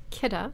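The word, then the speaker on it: Kidde: It's not Kitty. Although, no, there was actually a slide in their presentation on how to pronounce it. It's, where are they? What kind? What car is it? German? – I don't know. I don't know. – But it's K-I-D-D-E. – It's K-I-D-D-E.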